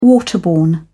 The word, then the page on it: waterborne: British English